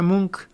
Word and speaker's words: Munch: Se escribe Munch y se pronuncia Munch y Edward Munch.